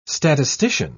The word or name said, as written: statistician